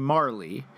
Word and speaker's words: Majerle: So M-A-J-E-R-L-E, Marley. Majerl, I think, was how one mm-hmm. might mispronounce it. – Uh-huh. – Had you not been a huge fan of the Phoenix Suns in the 1990s, like Simon and I were.